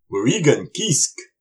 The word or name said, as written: Wurikun kisk!